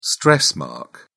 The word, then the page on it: stress mark: British English